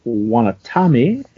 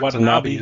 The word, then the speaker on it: Watanabe: And our cover is by Gil Kane and Frank Giacoya and Irving Watanabe for the colors. – Isn't it Watanabe, isn't it? – Watanabe? Watanabe? – Yeah, Watanabe.